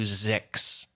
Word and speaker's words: Zyxx: Mujan Zulfagari is one of the creators and performers of a wonderful comedy podcast called Mission to Zix. And I know this is audio. Zix is spelled Z-Y-X-X. Mission to Z-Y-X-X.